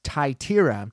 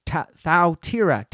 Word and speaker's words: Thyatira: For years, I pronounced that as Thyatira, but it's Thyatira. Thyatira is the pronunciation of this church.